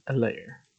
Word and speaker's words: Helaire: The H is silent. You know, he said, in fact, Clyde told you. He just stays silent like the H in Helaire.